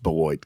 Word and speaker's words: Beloit: In fact, he's going to reference Rockford, Illinois and Beloit, Wisconsin, you'd be very upset that it's pronounced Beloit because it looks like blah. Very French for you.